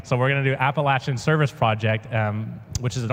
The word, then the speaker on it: Appalachian: I have now learned that they pronounce it Appalachian out there and not Appalachian. I've been trying really hard to train myself so I don't say the wrong thing.